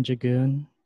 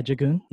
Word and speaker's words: Diagon: Last name, Jagoon. Spelled like Diagon, like the alley. Ooh. – That's your real last name? – Yeah, Jagoon.